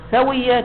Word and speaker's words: ثوية: ثوية كيف تلفظها يقول بفتح المثلثة س مثلثة يعني ثلاث نقاط لا هي ساء لا هي نون ث وكسر الواو ثوي وتشديد الياء المثنات المثنات يعني بها نقطتين مو باء